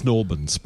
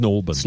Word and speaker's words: St Albans: It's Snorbans. Pronounce it correctly. – What? – Snorbans. – Snorbans, like Bran.